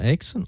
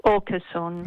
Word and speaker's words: Åkesson: Ecco, si pronuncia Eckerson o Oxen? – Åkesson, Jimmy Åkesson, ma siete perdonati.